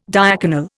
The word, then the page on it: diaconal: Indian